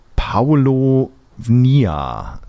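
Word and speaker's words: Paulownia: Und dann erzählt er mir ganz stolz von einem Holz, und ich muss das ablesen, das ist Paolo Nia, so wird es ausgesprochen.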